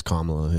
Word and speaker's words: Kamala: It's Kamala, yeah.